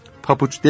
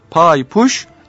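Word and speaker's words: paypuş: evet burada Leyla Hanım papuç demiyor, pahayı buş diyor. Efendim pay ayak demektir, puş örten demektir. Pay puş ayak örten. Leyla Hanım kitabında papuç değil diye not düşmüş. Bu yanlış telaffuza kızmış.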